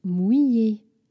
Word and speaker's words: mouillé: And she gets to learn the word wet, which is mouillé in French, which can be a challenging word to pronounce. So I wanted to help you with that. Mouillé.